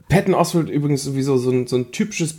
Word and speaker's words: Patton Oswalt: Ich weiß gar nicht, wie spricht man denn aus? Patton Oswalt? – Patton Oswalt, ja.